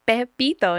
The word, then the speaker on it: Pepito: His real name is Pepito. And my dad couldn't pronounce it. And he used to call him Papito, Papito. And I was like, Dad, you're calling him like Papito, like he's your gay buddy. – Like daddy? – Yeah. And he goes, but how else do you say it? And I go, you know, Pepito.